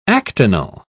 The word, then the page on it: actinal: British English